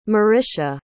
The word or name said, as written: Marisha